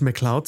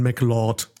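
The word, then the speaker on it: MacLeod: aufgefallen ist von Highlander, sie sagen die ganze Zeit nicht McLeod, sondern McLord, weil sie es nicht aussprechen können. sie, ich bin Connor McLord vom Clan der McLords. Und denkst, oh boy, das ist nicht schottisch, das ist falsch.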